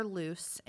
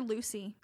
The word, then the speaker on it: Luce: You call her Luce in this series. – I called her Lucy. It's spelled L-U-C-E. I always called her Lucy.